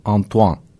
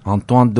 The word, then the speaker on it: Antoine: Anton dedim ama Antoine olacaktı. Antoine de Saint-Exupéry şeklinde telaffuz edilmesi gerekiyormuş. Bu bilgi de cebimizde dursun.